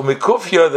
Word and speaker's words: mikufya: So mikufya, the term mikufya, pronounced mikufya, is an Aramaic phrase that originates from the Talmud.